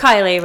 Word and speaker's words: Keily: It is How's it pronounced Keily, right? – Yes, ma'am.